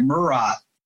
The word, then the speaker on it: Murat: How do you pronounce the venue? The Murat? The Murat? – I say it Murat.